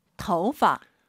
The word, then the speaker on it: 头发: 더파. – 오유는 어우예요. 도우 하지 마시고 터우예요. 이성경성이니까 아하 더파. 재밌는 건 이 파를 사성으로 발음을 정말 많이 해요.